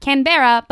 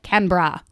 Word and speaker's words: Canberra: Also, thank you so much for correcting me that it is not Canberra, but Canberra.